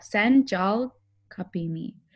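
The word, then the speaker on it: Sen Çal Kapımı: Senjal? Jal, Sen Jal Kapimi, but the Jal is C-A-L.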